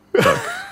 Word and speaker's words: Dacq: Wie spricht man die denn aus, die gute Muriel Duck? – Duck. – D-A-C-Q. Ja.